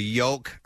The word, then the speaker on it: yolk: So you have the yolk. – Your brain has the shell on it. – I pronounce the L in yolk, yes.